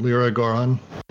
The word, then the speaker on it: Leroi-Gourhan: Short version, Leroi-Gourhan.